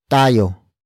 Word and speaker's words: tayo: For example, manguhuli tayo ng manok mamaya. Manguhuli tayo ng manok mamaya. Manguhuli tayo ng manok mamaya. It means we will catch some chickens later. Word for word it's manghuhuli, tayo, nang, manok, mamaya.